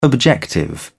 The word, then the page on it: objective: British English